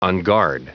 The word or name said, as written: unguard